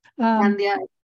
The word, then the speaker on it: Candia: Candia.